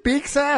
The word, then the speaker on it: pizza: Pero en Guadalajara no dicen pizza. – Pero el Canelo dice, a ver, vele a reclamar. – Canelo, se dice pizza con T. Es doble Z en italiano, se pronuncia T.